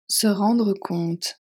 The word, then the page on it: se rendre compte: French